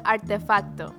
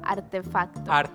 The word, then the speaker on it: artefacto: So you say it's artefacto. – Okay, artefacto. – Arti or arte? – Artefacto. – Artefacto. – Yeah. Artefacto. – There you go.